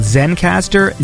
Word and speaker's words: Zencastr: It's called Zencastr, Z E N C A S T R. That's it. I don't know why. I should put the emphasis on the R there.